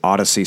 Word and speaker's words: Audacy: The closest direct competitor to Atlas is Odyssey Space. That's spelled A-U-D-A-C-Y.